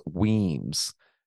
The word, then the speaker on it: Wemyss: And at the same time, he sent Major James Weems. It's spelled W-E-M-Y-S-S, but it's pronounced Weems.